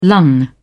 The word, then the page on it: lung: British English